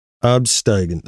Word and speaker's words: absteigend: I believe that's how I'm pronouncing it correctly. Let's see. I have a little mic. Hold on just a second. Here's a little microphone. Hold on. – Absteigend. – Okay. Okay. So I was kind of close.